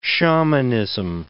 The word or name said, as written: shamanism